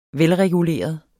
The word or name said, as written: velreguleret